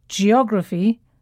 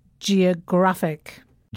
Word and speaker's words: geographic: When we say adjectives that end with IC, the stress falls on the penultimate syllable. That's the syllable just before the last one. So it's geography, but geographic.